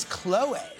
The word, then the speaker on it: Khloé: Yes, it's Khloé. Khloé is the name. There's a little accent over the name.